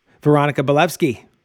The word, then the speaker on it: Weronika Balewski: And we are here with one of my favorite people, the one and only Weronika Balewski.